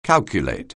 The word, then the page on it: calculate: anglais